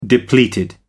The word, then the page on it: depleted: American English